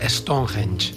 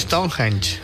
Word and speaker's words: Stonehenge: Eres la persona que mejor he escuchado pronunciar Stonehenge. – Stonehenge. – Se dice Stonehenge.